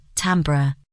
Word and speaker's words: timbre: Again, it's pronounced timbre. So, unfortunately, and in an exceedingly rare turn of events, Chris was right.